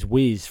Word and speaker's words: Weeze: I was going to be based in Dusseldorf Weeze Airport, which is Wies for any English speakers. It's W E E Z E, which in German is Weeze.